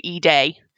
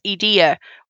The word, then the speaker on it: Edea: And it's like, no, it's Edea. Well, why is it Edea? Why the stress on the E? Well, if you look at how it's spelled in Japanese, it looks like it says Idea. And I has an E sound in Japanese, so Edea.